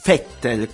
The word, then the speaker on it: Vettel: Mi raccomando, si scrive Vettel, ma si legge Vettel con la F.